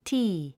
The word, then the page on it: tea: tíː; ティー